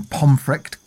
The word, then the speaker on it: Pontefract: Now, Richard II was overthrown by his cousin, Henry IV, in September 1399, and died mysteriously in Pomfret Castle. Now, this is spelt Pontefract, P-O-N-T-E-F-R-A-C-T, but because it's Yorkshire, it's said funny.